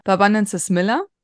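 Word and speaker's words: Aloe barbadensis Miller: Wir sprechen immer von der Barbanensis Miller. Marc, habe ich es richtig ausgesprochen? Ja, hast du.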